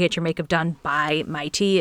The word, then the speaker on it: Maitee: So her name looks like Maddie Miles, but you pronounce her first name Mighty. So it's spelled M-A-I-T-E-E, Mighty Miles.